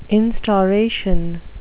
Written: instauration

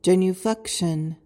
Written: genuflection

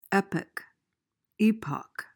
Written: epoch

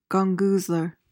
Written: gongoozler

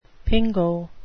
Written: pingo

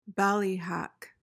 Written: ballyhack

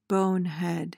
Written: bonehead

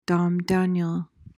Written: domdaniel

